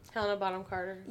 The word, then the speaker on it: Helena Bonham Carter: Helena Bonham Carter.